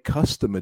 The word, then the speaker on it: customer: So we're going to break customer down into three. No? Three syllables. Customer. Customer. And Aline, the stress. Where do we put the stress? – Is it in the first one? Customer? – Oh, right.